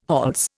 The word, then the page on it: hols: Indian